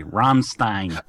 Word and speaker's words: Rammstein: let me pronounce it the American way. Rammstein.